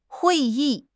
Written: hui4 yi4

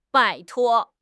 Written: 拜托

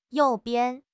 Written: you4 bian5

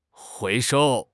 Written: 回收

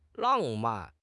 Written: lang4 man4

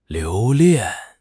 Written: 留恋

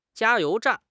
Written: jia1 you2 zhan4

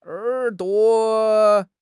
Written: er3 duo5

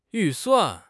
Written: 预算

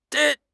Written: di1

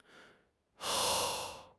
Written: Yuan2

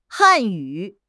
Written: Han4 yu3

